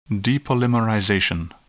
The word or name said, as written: depolymerization